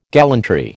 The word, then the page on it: gallantry: American English